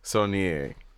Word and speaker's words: Saulnier: This is the one I don't know how to pronounce. Jeremy Saulnier. – Saulnier.